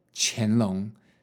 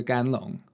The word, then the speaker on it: 乾隆: 然后后来好像是我的老板说：“ 呃，大文那个字要要念干乾隆。” 那个不是干龙，那那个时候我觉得我好倒霉，因为好像旁边的观光客也觉得很呃，觉得就是谁会把这个字念成肝？